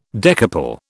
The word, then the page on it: decouple: British English